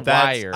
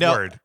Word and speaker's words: Wyrd: But Word is spelled W-Y-R-D. – Yeah. – Oh, so it might be wired. – No, you're right. It's Word.